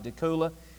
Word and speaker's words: Decatur: I think you pronounced that right, Decatur.